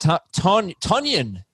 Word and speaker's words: Tonyan: Robert Tonyan, it's pronounced. – Yeah, right. Mm-hmm. – Robert Tonyan is questionable.